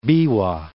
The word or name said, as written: Biwa